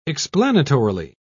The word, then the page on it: explanatorily: British English